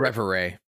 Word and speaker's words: Reverie: It's a game starring Polly, an upbeat young woman who can travel between our world and Revere. Revere? I don't know how to say it. It's a French word. Reverie. – Reverie, thank you.